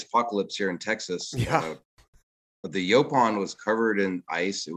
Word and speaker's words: Yaupon: So the Yopan, it's pronounced Yopan, right? – It comes from a Catawban word. It means little tree.